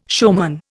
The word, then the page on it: showman: Indian